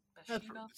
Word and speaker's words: Bathsheba: Bathsheba. I think that's how you say it. – Yeah. Bathsheba.